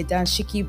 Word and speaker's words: dashiki: it's actually pronounced as Danshiki. That's D A N S H I K I. But some other people just type it as D A S H I K I dashiki.